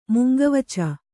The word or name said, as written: ಮುಂಗವಚ